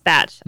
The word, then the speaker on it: Bach: Bach. Actually, it's Batch. It's pronounced Batch.